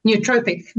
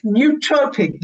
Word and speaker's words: nootropic: Nootropic. – Nootropic.